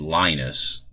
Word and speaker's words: Linus: Well, if the e-reader is American, we pronounce his name Linus, you know, like on the peanuts.